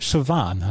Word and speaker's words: Siobhan: Ich habe es bei YouTube nachgeguckt, wie man den Namen ausspricht. Siobhan heißt sie.